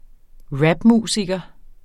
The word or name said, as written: rapmusiker